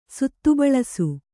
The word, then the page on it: ಸುತ್ತುಬಳಸು: suttu baḷasu